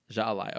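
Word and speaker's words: jai alai: Okay, it's pronounced Jaalai. – Jaalai, okay.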